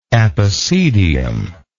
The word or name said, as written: epicedium